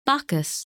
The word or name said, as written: Bacchus